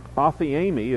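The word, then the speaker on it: aphiemi (ἀφίημι): The words at the top, the one on the left, aphesis, is the word, is the noun, aphiemi is how you pronounce the other word, and that's the verb. The word charis, C-H-A-R-I-S, is the word for grace.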